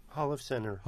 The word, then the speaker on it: Holofcener: I never quite known how to pronounce the last name of the director. Nicole Holofcener? Holofcener? I don't know. New York guys, do you know how to say it? – Holofcener.